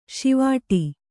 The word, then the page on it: ಷೀವಟ: Kannada